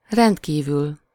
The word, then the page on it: extremely: [ɪks.ˈtriːm.li]